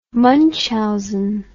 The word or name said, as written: Munchausen